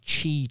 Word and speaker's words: Qi: several other devices have Qi charging. That's Qi, Qi for short. That's how it's pronounced.